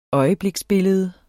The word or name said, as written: øjebliksbillede